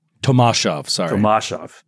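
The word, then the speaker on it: Tomaszów Lubelski: I think it's pronounced Tomashov Lubelski. – Tomashov.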